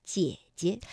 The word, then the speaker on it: 姐姐: 那么姐姐本来啊，这个赏声也应该读二幺四，姐。但是当连读的时候，第一个姐呢，我们就把它读成二幺，姐姐。